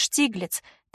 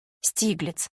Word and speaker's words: Штиглиц: Ее хозяином и организатором выставок был коллекционер искусства и фотограф, большой поклонник европейской живописи, его звали Альфред Штиглиц, также его фамилию произносят на русском «Штиглиц».